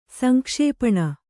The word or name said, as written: ಸಂಕ್ಷೇಪಣ